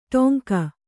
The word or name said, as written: ಡೊಂಕ